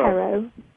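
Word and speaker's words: Q'ero: Now, the Quero, am I pronouncing that correctly? They're the medicine people of the Andes. The Quero. – Quero. Yeah.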